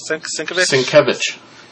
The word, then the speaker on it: Sienkiewicz: And how do you say it? Sienkiewicz? – Sienkiewicz. – Sienkiewicz, yeah.